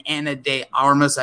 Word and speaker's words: Ana de Armas: And Ana de Armas, I believe I'm saying her name correctly.